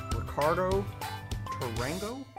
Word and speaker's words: Ricardo Tarango: I have Ricardo Tarango.